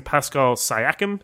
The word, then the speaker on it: Pascal Siakam: Pascal Siakam, is that how you pronounce his name? – Yeah.